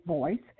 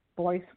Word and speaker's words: Voyce: You can follow him on Twitter, at Royce, R-O-Y-C-E, D-A, as in dog, D-A, voice, V-O-Y-C-E, voice rhyming with Royce.